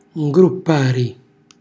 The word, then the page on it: ngruppari: calabrese